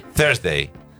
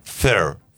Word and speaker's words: Thursday: Vamos a separar la palabra en dos. Thur. – Thur. – Agregué una vez. Thursday.